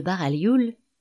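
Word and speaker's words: Baraglioul: La famille de Baralioul, le GL se prononce en L mouillé à l'italienne, comme dans Breuil, duc de Breuil, et dans Millionnaire, est originaire de Parme.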